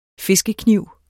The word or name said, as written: fiskekniv